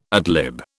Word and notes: ad lib: British English

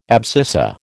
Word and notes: abscissa: American English

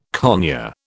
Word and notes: conure: British English

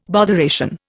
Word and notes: botheration: Indian

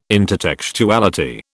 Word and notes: intertextuality: British English

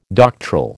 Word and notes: doctoral: American English

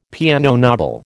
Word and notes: piano nobile: American English

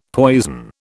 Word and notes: poison: British English

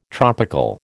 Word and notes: tropical: American English

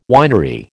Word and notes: winery: American English

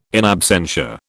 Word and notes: in absentia: British English

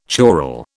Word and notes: choral: British English

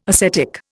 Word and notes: acetic: Indian

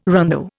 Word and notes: rondeau: Indian

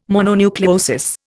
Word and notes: mononucleosis: Indian

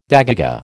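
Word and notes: dégagé: American English

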